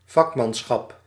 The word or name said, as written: savoir-faire